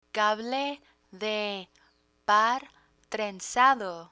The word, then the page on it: cable de par trenzado: spanish